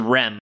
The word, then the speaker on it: R.E.M.: Mine is Rem. – Rem? – Yes. – Ah, from anime out of context. – Sure. – You know the band is pronounced R-E-M, right? Like, nobody calls it Rem. Like, nobody knows what you're talking about. – R-E-M, yes.